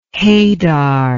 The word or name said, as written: hadar